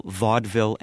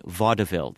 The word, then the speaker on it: vaudeville: Also, I warn spellers if there are multiple correct pronunciations, like, for example, vaudeville and vaudeville. They're both correct. They're both listed in the dictionary.